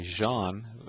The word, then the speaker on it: Jean: I know my name is Jean. It's John in French.